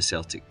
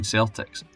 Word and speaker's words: Celtic: Walfrid wanted the name to be spoken as Celtic, which did not happen. An 1892 newspaper article also said this was the way that Davitt pronounced the name, although again it was certainly not a title he gave the club.